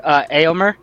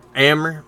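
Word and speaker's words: Éomer: Lord Eomer. – Eomer, yeah.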